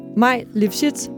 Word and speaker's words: Maj Lifshitz: Maj Lifshitz, velkommen. – Ej, det var perfekt. Det var perfekt udtalt.